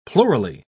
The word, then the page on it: plurally: British English